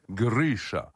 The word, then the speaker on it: Grisha: Grisha. Et c'est comme ça que ça se prononce.